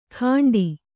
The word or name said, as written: ಖಂಡಿ